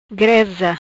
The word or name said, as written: Greza